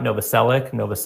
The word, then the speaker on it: Novoselic: I don't know if it's a hard C at the end or not. Novoselic. – I think you got it right.